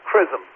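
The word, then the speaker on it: XRISM: It's called CRISM, but it's spelled with an X, X-R-I-S-M.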